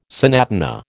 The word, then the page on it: sonatina: American English